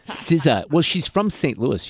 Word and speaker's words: SZA: But you had nominations in here from Bad Bunny, Sabrina Carpenter, Doechii, Billie Eilish, Lady Gaga, Kendrick Lamar with SZA, Chappell Roan, and Bruno Mars.